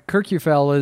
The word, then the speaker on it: Kirkjufell: but is it Kirkjufell? – Yeah, Kirkjufell is a waterfall, and we went to that the next day, and it was a little bit more of a drive.